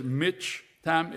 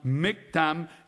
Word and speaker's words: miktam: It is pronounced, even though it says mitch-tam, it's pronounced miktam, and it means poem.